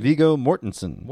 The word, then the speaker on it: Mortensen: Viggo Mortensen.